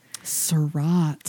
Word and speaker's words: Surratt: Surratt. How do you spell that? – S-U-R-R-A-T-T.